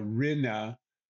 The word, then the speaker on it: Rinne: Uh, last last name would be pronounced uh, Rina, kind of like that. It, it, it doesn't really translate.